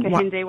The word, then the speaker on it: Kehinde: Kehinde Wiley.